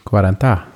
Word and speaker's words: quaranta: Wie spricht das in Italiener aus? – Quaranta.